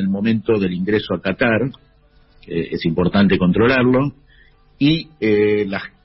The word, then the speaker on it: Qatar: Qatar. – Qatar, Qatar, así como suena.